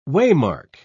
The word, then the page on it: waymark: British English